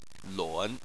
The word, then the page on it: lun: 3